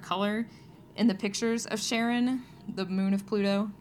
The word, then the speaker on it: Charon: And yes, that's how you pronounce that moon. I'm sure I'll get hate mail about that too. It's named after the guy's wife. That's why it's pronounced Charon, but it's spelled like Karen because it's a mythological person.